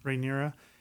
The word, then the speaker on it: Rhaenyra: Rhaenyra.